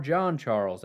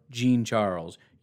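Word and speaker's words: Jean-Charles: He said it kind of fast, but there's clearly an E sound in there. You don't say John, and there's like an E sound in the middle. John with an E sound in the middle is Gene. Shamar Gene Charles.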